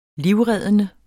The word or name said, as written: livreddende